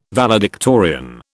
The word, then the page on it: valedictorian: British English